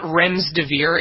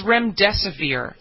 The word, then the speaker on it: Remdesivir: No, you didn't. It's not Remdesivir. It's Remdesivir. Like, spell it out phonetically, Mr. Former English teacher.